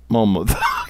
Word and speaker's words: Monmouth: Monmouth? Spell it. – M-O-N-M-O-U-T-H. – Monmouth. – Unless you want to say Monmouth.